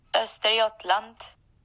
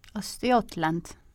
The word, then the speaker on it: Östergötland: Östergötland. – Östergötland.